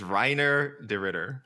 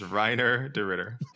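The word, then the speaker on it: Reinier de Ritter: It is Reiner de Ritter. It is Reiner de Ritter. It's not Hainier. It's not Renier. It is Hainier de Ritter. It's Reiner de Ritter.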